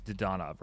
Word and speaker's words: Dadonov: I used to say Dadonov, and I it's Dadonov, right? – Yeah.